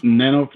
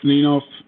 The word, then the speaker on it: Nenov: My guest for this episode is Georgi Nenov? Nenov? Did I say it right? Nenov? – Nenov, Nenov. Nenov? It doesn't matter.